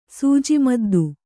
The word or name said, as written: ಸೂಜಿಮದ್ದು